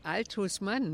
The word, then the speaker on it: Althusmann: Althusnhausen. Wie wird er denn nun ausgesprochen? – Nein, Althusmann, ne?